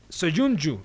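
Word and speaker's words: Söyüncü: Bueno, eh, el agente de Soyunju, ¿lo dije bien o no? Sí. Perfecto.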